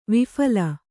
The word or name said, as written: ವಿಫಲ